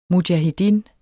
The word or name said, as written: mujahedin